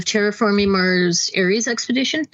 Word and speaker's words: Terraforming Mars Ares Expedition: So I started off tonight teaching a game of Terraforming Mars Ares Expedition.